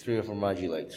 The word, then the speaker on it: Trio Formaggi: Trio Formaggi, three cheese pizza.